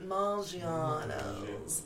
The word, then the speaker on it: Maggiano's: You have to say it like that. Maggiano's.